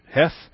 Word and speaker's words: Heth: In your notes, it looks like Heth, H-E-T-H, Heth, but the guttural sound of the Heth is such that you really want to get a good hawking load of phlegm in the back of your throat to pronounce Heth.